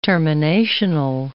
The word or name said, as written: terminational